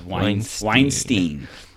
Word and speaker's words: Weinstein: Is that how you pronounce that? Weinstein? Weinstein? – Yes, Weinstein. Harvey Weinstein.